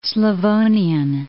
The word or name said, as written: Slavonian